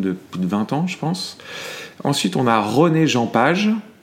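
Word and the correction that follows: Regé-Jean: Je pense que je le prononce vraiment pas bien. Non, c'est Reggie Jean Page.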